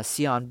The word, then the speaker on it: Xian: Before we end, another new artist that it dropped a recent project called Sion Bell. If I'm pronouncing the name, because it says X-I-A-N, so it's a bit crazy to pronounce.